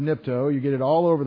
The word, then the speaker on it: nipto: N-I-P-T-O. Nipto. N, is that big enough to see? Yeah. N-I-P-T-O. Long O. Nipto. Almost sounds take a dip.